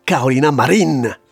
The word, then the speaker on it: Carolina Marín: Ce sera Carolina Marín, dit la Scream Queen, la championne de badminton.